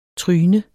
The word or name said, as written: tryne